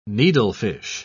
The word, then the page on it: needlefish: British English